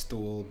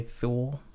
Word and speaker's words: Thule: I read it as Thule, but, you know, as in Yule.